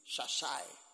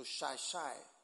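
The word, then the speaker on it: Xai-Xai: And then look at X-A. It's pronounced in Portuguese, Shashai. So Shashai.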